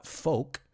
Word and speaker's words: folk: De hecho, vamos a ver esta palabra folk right? We don't pronounce that L. We don't say folk, we say folk.